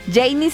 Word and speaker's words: Jany: Por cierto, también así me pueden encontrar en redes sociales, arroba Janie Capistrán. Janie se escribe así, JANY, ¿ok?